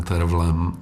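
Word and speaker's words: Terfel: A tahle nahrávka s Brynem Terflem, nebo Terflem, já velšskou výslovnost úplně neovládám. Někdo říká Tervl, někdo Terfl. A s Jamesem Levinem, no, tak to je taková ta parádní vypalovačka, kdy on si doopravdy s celým tím partem hraje.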